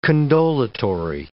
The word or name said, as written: condolatory